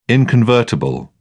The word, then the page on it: inconvertible: British English